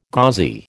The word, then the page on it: gauzy: American English